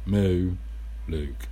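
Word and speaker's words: Muluc: Pronounced Mu Luke.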